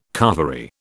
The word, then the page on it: carvery: British English